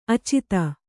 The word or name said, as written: ಆಚದು